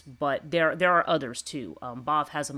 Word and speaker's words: Badb: There's Maka, there's Bav, which is spelled B-A-D-B. Those of you who have seen my book Maeve will recognize this Irish spelling.